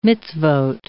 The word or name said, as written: mitzvoth